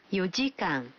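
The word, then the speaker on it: یو جیکان (四時間): البته تلفظ بعضی از عددها کمی فرق میکنه. 4 ساعت میشه یو جیکان.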